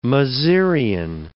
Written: mezereon

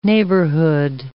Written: neighborhood

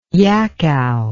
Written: yakow

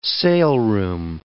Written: saleroom

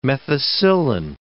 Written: methicillin

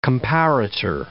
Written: comparator

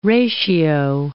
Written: ratio

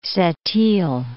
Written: Sept-iles

